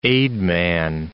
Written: aidman

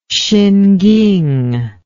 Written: Hsinking

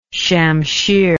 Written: shamshir

